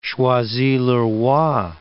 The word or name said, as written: Choisy-le-Roi